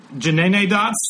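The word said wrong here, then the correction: Jennyanydots: Jenny Any Dots.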